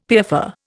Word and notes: piaffer: Indian

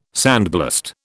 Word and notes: sandblast: British English

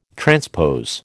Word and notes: transpose: American English

